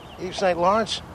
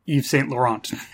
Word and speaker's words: Yves Saint Laurent: The joke is pronounced Yves Saint Laurent. – I get it now. – Not Yves Saint Lawrence.